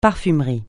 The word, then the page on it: parfumerie: francais